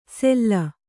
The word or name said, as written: ಸೆಲ್ಲ